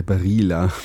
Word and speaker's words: Barilla: Wie das hast du es denn ausgesprochen? – Ja, Barilla. – Ach so. – Ich dachte, ich spreche so ein bisschen Italienisch aus. – Ich glaube, das heißt tatsächlich auch so, ja. – Ach, ja, dann habe ich es also richtig ausgesprochen. – Du hast es richtig ausgesprochen.